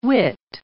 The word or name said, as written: wit